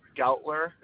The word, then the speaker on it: Goughler: My name is Eric, E-R-I-C. – And the last name? – Gautler is spelled G-O-U-G-H-L-E-R. – G-O-U-G-H-L-E-R? – Yes.